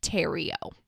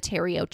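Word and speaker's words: Thériault: But it's pronounced, I'm pretty sure, Roche and then Terrio.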